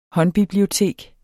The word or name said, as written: håndbibliotek